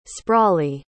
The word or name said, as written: sprawly